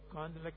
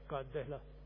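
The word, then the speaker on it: کاندھلہ: کاندلہ، وہاں کے لوگ کاندلہ کہتے ہیں، ہمارے لوگ پورا تلفظ کرتے کاندہلہ،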